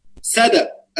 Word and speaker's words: سدى: فعندي سدى الثوب ولحمة الثوب، سدى الثوب هكذا تنطق، سدى الثوب ولحمة، وفي لغة لحمة وبعضهم غلطها لكن خلينا نقول لحمة الثوب.